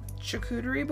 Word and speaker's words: charcuterie: Something nourishing, like a charcuterie board. Did I pronounce that even a little bit close? – Yeah, you did.